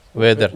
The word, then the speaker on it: Werder: Wider Bremen. – Bremen. Yeah. Wider Bremen. Is that how you pronounce it? Wider. – Wider. – Werder. – Werder Bremen.